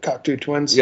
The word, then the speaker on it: Cocteau Twins: Was it the Cocteau Twins? Is that what it was pronounced? – Yep, Cocteau Twins.